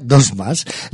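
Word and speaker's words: 2+: Se escribe con número 2 y el signo más. Se pronuncia Dos Más.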